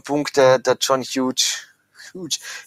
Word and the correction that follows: Hughes: das ist ein Scheißname. Wieso kann er nicht Huge oder wie spricht man den wirklich aus? – Hughes. – Sagt der selber, my name is John Hughes, weil man weiß es nicht genau, gell?